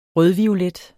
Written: rødviolet

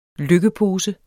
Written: lykkepose

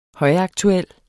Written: højaktuel

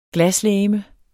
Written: glaslegeme

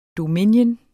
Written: dominion

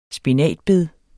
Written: spinatbed

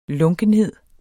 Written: lunkenhed

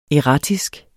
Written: erratisk

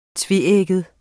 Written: tveægget

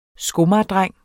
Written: skomagerdreng

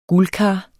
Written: guldkar

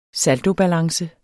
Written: saldobalance

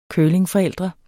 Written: curlingforældre

